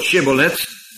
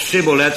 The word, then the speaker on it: Shibboleth: De Shin die aan het begin staat van dit woord Shibboleth. Of Sibboleth. Het is in beide gevallen dezelfde letter, maar in een verschillende uitspraak.